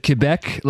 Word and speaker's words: Quebec: You even said Quebec, like you were pronunciation down.